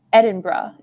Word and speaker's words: Edinburgh: So I went to Vienna and went to Edinburgh. Is that how you pronounce it? – Yeah. – Nice. Yeah. I thought it was Edinburgh at first. – I thought it was Edinburgh too. And then luckily Brad, who was one of the other people who we interned with, he had gone the year before.